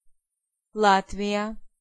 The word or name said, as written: Latvia